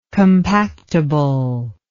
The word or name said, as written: compactibility